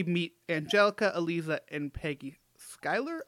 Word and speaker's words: Schuyler: Schuyler, yeah.